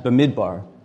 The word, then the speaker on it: Bamidbar: These are the Hebrew letters for a B, an M, a D, a B, and an R, reading right to left, the way we do in Hebrew, pronounced Bamidbar. And it means numbers.